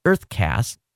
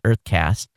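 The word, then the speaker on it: UrtheCast: There's a Canadian startup called Uthercast, pronounced Earthcast. Okay, U-R-T-H-E, Earth. Okay, it's spelled with a U, but they pronounce it Earthcast.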